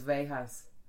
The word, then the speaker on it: Veja: I Googled it. And they said it's like deja vu. So Veja vu.